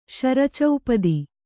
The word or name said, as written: ಶರಚೌಪದಿ